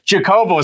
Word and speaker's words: Jacobo: Jordan Jacobo or Jacobo, as my parents prefer.